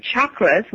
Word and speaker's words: chakras: Yes, and chakras, which is pronounced with a ch and not an sh, some people say that there's 108.